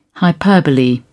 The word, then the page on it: hyperbole: British English